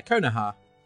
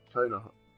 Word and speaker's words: Konoha: Yeah, Konoha. – Konoha. – Yeah, it's long. It does have a longer title. Konohagakure. – Yeah, that's the one. – But Konoha for sure.